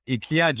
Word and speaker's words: Iklia: I hope I pronounced that correctly. – Yes, that's perfect, Adrian. Thank you so much. The name of the company is Iklia.